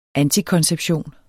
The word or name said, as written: antikonception